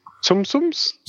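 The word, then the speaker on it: Tsum Tsum: Now, speaking of likenesses done in toy form, my next bit of news is the San Diego Comic-Con Captain America commemorative Zum Zum. Is that how you pronounce it? – What? – Who cares? – Sum Sums? – Sum Sum, yeah.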